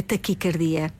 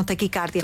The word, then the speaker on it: taquicardia: O ai é fechado, a forma correta, a pronúncia correta é taquicardia e não taquicárdia.